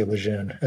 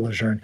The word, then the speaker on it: Lejeune: They keep changing the pronunciation of it, but I think we're supposed to say Lejeune as opposed to Lejeune.